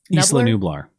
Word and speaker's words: Isla Nublar: Is that how you say it? – Isla Nublar. Yeah, the Jurassic Park island.